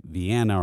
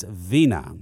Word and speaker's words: Vina: You know, they say like Vienna or Vinia. You know, they don't know how to say it, but it's Vina.